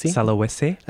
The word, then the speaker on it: Sulawesi: 13 areas under attack include the tourist island of Bali, Java, Papua, North Sumatra, Maluku, and parts of Sulawesi. Oh, hopefully I've got that right. – Sulawesi. That's okay, yep.